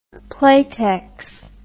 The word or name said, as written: playtex